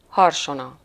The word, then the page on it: clairon: France; [klɛ.ʁɔ̃]